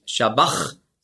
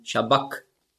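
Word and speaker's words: sabachthani: Und zwar kommt das daher, Matthäus schreibt ja Griechisch. Im Griechischen gibt es keinen Sch-Laut. Es gibt nur das S. Und da musst du den Sch-Laut mit einem S schreiben. Und das CH im Griechischen, das ist ja ein Buchstabe, das Chi, das hat man ausgesprochen so wie ein C hinten im Gaumen. K. Also nicht Shabbach, sondern Shabbak.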